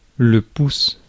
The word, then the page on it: in: /ɪn/